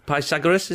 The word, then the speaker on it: Pythagoras: But Pythagoras is how you say it.